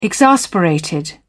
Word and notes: exasperated: British English

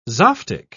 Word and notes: zoftig: British English

